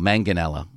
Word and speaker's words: Manganiello: Joe Manganiello was on. Am I right about that, Horsefly? – Yeah, he was on. But Joe Manganiello and Ben. – Manganiello, not Manganiello. Manganiello. I'm sorry.